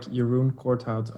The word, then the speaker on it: Jeroen Cotart: No, you had it right earlier, but you were going a bit fast now. It's more like Jeroen Cotart, at least with an English accent.